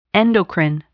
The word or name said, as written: endocrine